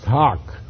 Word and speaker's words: hough: H-O-U-G-H is pronounced hock.